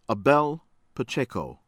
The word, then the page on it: Abel Pacheco: American English; from Costa Rica